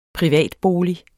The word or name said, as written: privatbolig